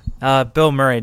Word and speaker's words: Bill Murray: Bill Murray, just like the guy.